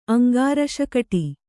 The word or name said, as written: ಅಂಗಾರಶಕಟಿ